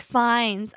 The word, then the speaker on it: Fiennes: Seems like he's like, oh, he's related to I'm pronouncing his name wrong. Ralph Fiennes. – Oh, it's Fiennes. Okay.